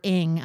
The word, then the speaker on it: Ng: The last name is NG. And what I love about her Twitter account is it's pronounced underscore Ng, I-N-G. So it's, she tells you it's Celeste Ng.